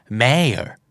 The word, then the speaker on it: mayor: สองคำนี้ออกเสียงว่า mayor กับ prayer ไม่ออกเสียง a แต่เป็นออกเสียง r นะครับแล้วท้ายคำออกนิดเดียวบางทีแทบไม่ได้ยินฟังเผินๆเราอาจจะได้ยินเขาออกเสียงว่า mae กับ prayer ด้วยซ้ำไปนะครับ mayor